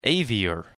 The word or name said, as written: Avior